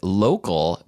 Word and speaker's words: LOCOL: And use the offer code LOCAL, but that's spelled LOCOL, for 10% off your first purchase.